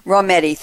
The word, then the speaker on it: Rometty: Rometty, thank you. Her full name, Virginia Marie, and a shortcut as Ginny, G-I-N-N-I, Rometty, 1957.